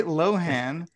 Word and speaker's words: Lohan: I just saw a headline that Lindsay Lohan, is that how you pronounce her last name? Lohan? Lohan? – Yeah. Yes. – Lindsay Lohan.